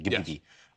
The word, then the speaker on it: Ghibli: Ghibli. – The correct pronunciation, Ghibli. Yes.